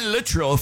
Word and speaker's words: Littrell: Animal with a K, Brian Littrell from the Backstreet Boys. Yeah, that's how it's pronounced.